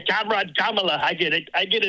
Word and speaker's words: Kamala: Sir, it's Kamala. That's how you say your name, Kamala. – Kamala. – Yeah, Kamala. – Let's do it together, Big Frud. Kamala. – No, Kamala. – Killing Kamala. – Kamala is how you say. You keep saying Kamala. – You keep saying Kamala. It's Kamala.